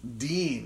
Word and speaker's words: Digne: I assume Digne was an African man. I think it's pronounced Dean is how they were pronouncing it watching that game.